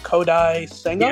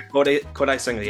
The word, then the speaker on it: Kodai Senga: Then they go and sign Justin Verlander and that guy from Japan, I believe it's pronounced Kodai Senga. – Yeah, Kodai Senga, yeah.